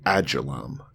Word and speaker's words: Adullam: It's a cave in Judah known as Adullam. I don't know how to say it. – You got it.